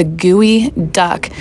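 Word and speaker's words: Gwe Duck: GUI Duck. And before you Google that, it is spelled G-W-E Duck.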